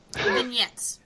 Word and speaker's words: vignettes: vignettes how do you say it? vignettes? Vignettes. vignettes. Oh, that, now I know.